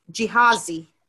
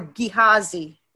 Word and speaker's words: Gehazi: So this is an interesting story, and we're going to look at the character in that story called Gehazi, or Gehazi, whichever you want to pronounce it, G-E-H-A-Z-I, Gehazi.